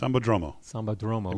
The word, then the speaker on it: Sambódromo: We'll be at the Sambódromo, which I probably don't quite pronounce that. – Sambódromo. It means Samba Road.